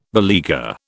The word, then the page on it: beleaguer: British English